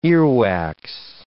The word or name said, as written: earwax